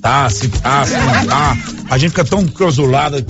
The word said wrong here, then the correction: enclausurado: Como é que fala encrausulado? – Encrausurado? – Não consegue, né? – Ô, seu Val, me deixa em paz, bicho. – Não consegue, né? – Consigo, senhor. É enclausurado.